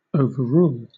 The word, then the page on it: overrule: British English